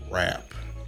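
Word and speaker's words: Raop: But episode one, deeper than rap. The O is silent. – Yeah. – The O is silent. It's deeper than rap. It's going to look like deeper than R.E.O.P., but it's deeper than rap.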